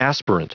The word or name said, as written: aspirant